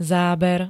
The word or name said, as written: záber